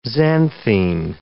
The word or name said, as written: xanthine